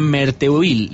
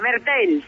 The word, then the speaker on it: Merteuil: Es un placer para mí el saludarla, y ella va a dar vida en esta obra a la Marquesa de Merteuil. No sé si lo he dicho bien, Carmen, ¿qué tal? Muy buenas. – Muy bien, Merteuil.